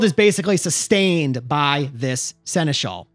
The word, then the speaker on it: Seneschal: You encounter a godlike being called the Seneschal. Is that how you pronounce that? – Yeah, Seneschal? – I think you're right.